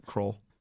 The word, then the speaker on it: Kroll: Nick Kroll, I believe is how it's pronounced. – Mm. – No? – It's Kroll.